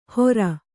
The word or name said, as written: ಹೋರ್